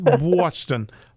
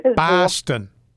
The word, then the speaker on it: Boston: Not Boston. Boston. Like there's an H in there.